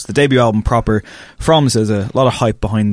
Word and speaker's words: SZA: It's SZA, and am I saying that correctly? – Because, yeah. – Okay, because I've like heard different variations on it. – RZA, JZA, SZA. – Okay, SZA.